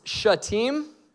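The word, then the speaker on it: Shittim: It says this, early that morning, Joshua and all the Israelites set out from, turns out that word is pronounced Shatim, and I've been saying it wrong this entire time.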